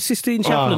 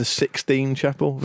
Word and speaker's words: Sistine Chapel: I think you'll find it's pronounced the Sixtine Chapel. You've embarrassed yourself there. Yeah. – Is it really? – No, of course it isn't.